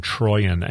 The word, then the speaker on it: Troyan: And it's a weird spelling, but it's pronounced Christine Troyan,